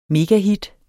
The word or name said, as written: megahit